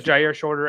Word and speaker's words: Jyaire Shorter: That would be Jyaire Shorter and Larry Nixon. I pronounced Jyaire's name correctly, correct? I yes, Jyaire Shorter and Larry Nixon the third.